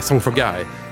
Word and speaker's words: Song for Guy: Nej, Song for Guy. Ja.